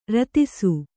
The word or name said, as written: ರೋದಿಸು